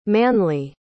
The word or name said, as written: manley